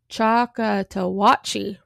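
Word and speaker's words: Choctawhatchee: Brayer's company, usually described as the Dale County Home Guard near the Choctaw River. I don't think I'm saying that right. It's Choctawatchee River.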